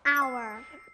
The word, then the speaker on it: hour: Hour. – 네, 아주 잘했어요. 여기 H 소리가 안 나요.